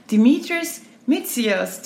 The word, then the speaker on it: Dimitrios Mitsios: I'm here at the Iconic Santorini Hotel for Travel Writers Radio with the resident manager, Dimitrios Mitsios. Did I say it correctly? – Perfect.